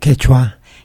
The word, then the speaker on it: Quechua: Quechua.